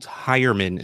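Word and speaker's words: Heuerman: Marvin Jones, Andy Dalton, Jeff, I'm told Hyerman is the correct way to say it, and OJ Howard.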